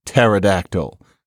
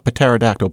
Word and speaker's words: pterodactyl: Why is it pterodactyl, for example? Why not pterodactyl? But you just heard why. We don't want to say that.